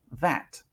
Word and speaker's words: that: Pasemos a la pronunciación de that. That. De nuevo, comienza con TH, así que la lengua entre los dientes. y luego la A la hago sonriendo. Es como una A sonriendo. That.